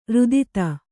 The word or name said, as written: ರುದಿತ